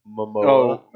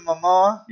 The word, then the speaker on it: Momoa: Momoa. – Oh, yeah. – Jason Momoa.